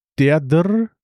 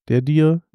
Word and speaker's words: Derdhr: Derdr, wir müssen uns auf irgendwas einigen, wie wir diesen Namen aussprechen. Derdr, der dir. – D-E-R-D-H-R. Ich habe in meinem Kopf immer der dir, warum auch immer, oder derdr. Derdr.